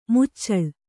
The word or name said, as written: ಮುಚ್ಚಲ